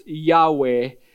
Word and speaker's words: Yahweh: It's the Hebrew word that we would pronounce Yahweh as a significant name.